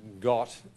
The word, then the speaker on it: Gad: Gad is pronounced Got in Hebrew, with the T for the D and so on.